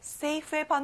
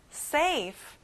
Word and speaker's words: safe: 안전한, safe. 끝에 f 발음까지 들리게 해주세요. safe.